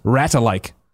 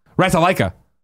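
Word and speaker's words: Ratalaika: Just a reminder, it's pronounced Ratalike. Ratalika. Say it all in order.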